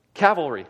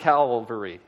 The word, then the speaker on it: cavalry: Cavalry, not calvary.